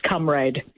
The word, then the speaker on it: comrade: Yes, and I think with comrade, it was originally pronounced comrade.